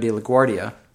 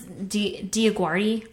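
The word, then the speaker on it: DioGuardi: So Bravo show Platinum Hit, hosted by Jewel and former American Idol judge Cara DeLaGuardia. Did I say it right? – No. – What the hell is her name? – It's DeLaGuardia. – DeLaGuardia. So it has nothing to do with LaGuardia Airport.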